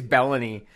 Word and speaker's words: Belloni: I hope I'm saying his name right. Baloney. Baloney? Here's Bellany. I heard Nigel snickering, so maybe it's Belloni, but it's B-E-L-L-O-N-I, Nigel.